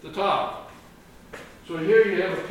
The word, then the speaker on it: Tav: Oath, we'd pronounce it like O-A-T-H in English, but it's Aleph, Vav, Tav. Or Thav, because it's a TH sound rather than just a T sound. So the Thav.